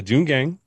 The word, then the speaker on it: Joongang: It's the Joongang Tongangang Broadcasting Company. – Spell it now. – Don't do it. – J-O-O-N gang. T-O-N-G Y-A-N-G Broadcasting Company.